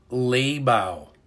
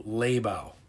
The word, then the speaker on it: Laibow: Her name is Dr. Rima Leibow. R-I-M-A, Rima. L-A-I-B-O-W, Leibow, I believe, is how you pronounce it.